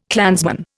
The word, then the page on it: clansman: Indian